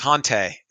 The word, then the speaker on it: Hante: Hante is not pronounced like auntie. – Oh, it's Hante? – Yeah, it's Hante.